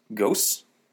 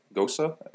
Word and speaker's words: Gose: So, based on what I learned from Duolingo, this should be pronounced Rittergutz, Rittergutz, Gose, Gosa. I've heard both pronunciations.